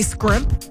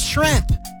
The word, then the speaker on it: shrimp: Now, for me, when someone can't say scrimp, or they say scrimp, like, act like you've had it before. It's shrimp. S-H-R-I-M-P.